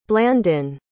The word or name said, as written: blandon